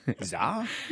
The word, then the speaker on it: SZA: When we learned how to say her name. – Yes. – We were like, thankfully. S-Z-A. Zah.